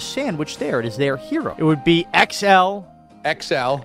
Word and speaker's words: gyro: It just, it's pronounced the way it's spelled. It's gyro. Enough with the fancy schmancy. We're not in Greece. – It's properly pronounced hero.